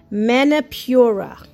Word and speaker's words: Manipura: which is pronounced Manipura.